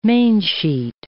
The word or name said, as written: mainsheet